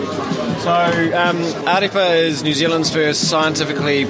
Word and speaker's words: Ārepa: Yeah, Ārepa.